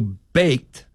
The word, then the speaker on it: baked: Whereas baked is the past tense of bake. Here, the ED is a grammatical ending that is pronounced simply as a T sound because it follows the unvoiced consonant K, so baked sounds like B-A-Y-K-T.